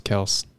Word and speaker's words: Kelce: Well, that's how like they said that their name's pronounced Kelce.